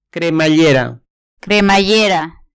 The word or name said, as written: cremallera